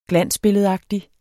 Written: glansbilledagtig

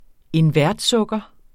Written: invertsukker

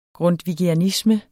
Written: grundtvigianisme

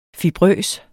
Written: fibrøs

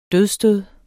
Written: dødsstød

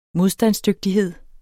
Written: modstandsdygtighed

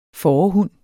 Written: fårehund

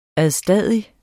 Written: ADSTADIGT

